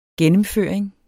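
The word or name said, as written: gennemføring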